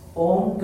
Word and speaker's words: Om: In Sanskrit language, Om consists of three syllables, A, U, and Ma.